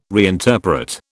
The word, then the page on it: reinterpret: British English